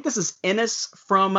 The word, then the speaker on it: Anas: It's an unusually written A-N-A-S, pronounced Ennis.